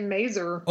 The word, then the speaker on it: Mazur: Oh, see, I was going to say Mazur. – Oh, Mazur. I like Mazur better. – I don't know. – It rhymes with laser.